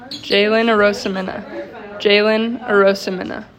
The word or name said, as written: Jaylyn Arosemena